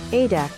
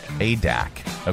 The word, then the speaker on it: Adak: Adak. – Adak.